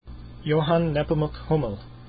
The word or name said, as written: hummel